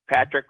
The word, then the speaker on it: Patrick: Patrick Christopher Chouinard. – Spell it. – P-A-T-R-I-C-K, Christopher, C-H-R-I-S-T-O-P-H-E-R, Chouinard, C-H-O-U-I-N-A-R-D.